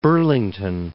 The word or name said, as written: Burlington